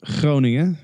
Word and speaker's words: Groningen: I went to university in Holland, in a town that's called Groningen, which is very hard to pronounce if you're not from Holland. You're welcome to give it a try. – Groningen. – It's not bad.